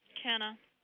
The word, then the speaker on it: Kenna: Kenna. – And how do you spell it? – K-E-N-N-A.